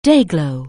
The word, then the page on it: Day-Glo®: anglais